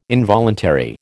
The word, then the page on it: involuntary: American English